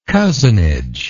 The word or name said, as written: cozenage